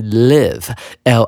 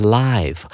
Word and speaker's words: live: 它原本呢是一个动词，指的就是 live，l i v e 是居住的意思。而形容词呢，念法会念成 live，发音是不同的哦。